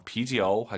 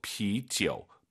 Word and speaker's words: 杯酒: 삼성이 마지막 글자면 올리지 마세요. 비지어우 하지 마시고 비지우. 반삼성으로 뚝 떨어지는 센스.